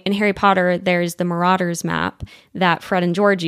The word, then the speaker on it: Marauder's: In British English, this is normally pronounced as marauders. You do want to stress on the second syllable.